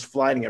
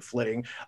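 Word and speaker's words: flyting: I want to say it is flitting. I thought it was flying at first, but I believe they pronounced it flitting, uh, which is the like Viking rap battle that you can do within the game.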